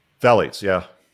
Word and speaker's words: phthalates: I literally just found out that there's phthalates, or however you pronounce that word, in Chipotle. – Phthalates, yeah.